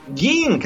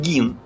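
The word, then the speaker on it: Gein: Гейн, как он у нас видит, на самом деле он Гин. Он Гин, хотя пишется как Гейн.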